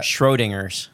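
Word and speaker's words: Schrödinger's: Schrodinger's cat. How do you say that? – Schrodinger's.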